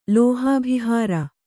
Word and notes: ಲೋಹಾಭಿಹಾರ: Kannada

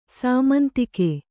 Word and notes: ಸಮ್ಮಂಧಿಗ: kannada

